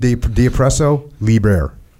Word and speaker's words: De oppresso liber: De oppresso liber. How do you say that? I can't believe I can't say that. De oppresso liber. Is that right? – I think you got it right.